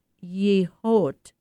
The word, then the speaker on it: Yehaw: The show, spelled Y-E-H-A-W and pronounced Yehote, includes 200 works showcasing contemporary Native American art, Native American Indian art.